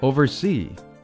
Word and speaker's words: oversee: Oversee，重音在后面，oversee，监视、监督，比如说监工，oversee work。